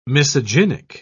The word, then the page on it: misogynic: British English